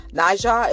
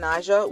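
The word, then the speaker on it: Nadji: Najah, as I'm saying his name right, it's spelled capital N, A as in girl, Apple, D-J-I, Rivera, was shot and killed last August in Tampa.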